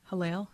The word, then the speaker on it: Hillel: So I Googled Hillel. Am I saying that right? – Yes.